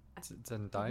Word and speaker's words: Zendaya: Is that how it's pronounced? Zendaya. – Zendaya.